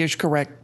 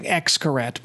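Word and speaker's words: Xcaret: So we have been to the Cancun area many times before the hotel was built, and we've seen the Ixcaret parks, which looks to our eyes as saying Ixcaret, but it's pronounced Ixcaret.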